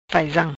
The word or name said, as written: Faizam